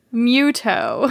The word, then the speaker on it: Mewtwo: So, sorry, what's it called? Mewtwo? – Muto. – Because Muto is just, like, they didn't mash the words together enough. It just sounds like Muto. – But it's spelled M-E-W. – Muto. – Toe. – Now, dash T-O.